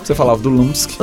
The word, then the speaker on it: Lumsk: Quarto bloco, uma banda chamada Lumsk. É isso? Será que é assim que fala? É assim Sim. – mesmo, né? Lumsk.